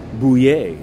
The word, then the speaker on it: Buyer: Now, yesterday we covered the arraignment of a former Indiana congressman, Stephen Bouyer. It's spelled Buyer,